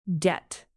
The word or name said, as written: debt